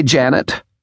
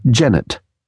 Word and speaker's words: Janet: Oh, hi, Janet. She betrayed herself as a foreigner by pronouncing the name to rhyme with "Gannett." Janet Wadman herself, like a good New Brunswicker, used the gentler Janet.